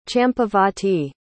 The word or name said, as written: champavati